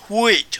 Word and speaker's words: huit: And it also can be pronounced similarly to the French huit. Huit, I assume hui, in old, huit in old French, or hui in modern French, if I got that right.